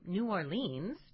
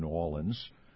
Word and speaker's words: New Orleans: Oh, I'm so sorry. Let me say it and get it right. New Orleans. – Close enough.